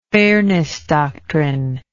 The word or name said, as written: fairness doctrine